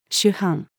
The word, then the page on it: 首班: female